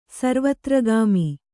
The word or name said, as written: ಸರ್ವತ್ರಗಾಮಿ